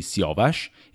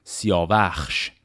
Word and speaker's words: سیاوش: اسم سیاوش رو با دو تلفظ فردوسی میگه یکی سیاوش یکی هم سیاوخش این هر دوی این تلفظ ها در داستان شاهنامه هست و معمولا تفاوتشون فقط برای جایی که در وزن جا میشه یا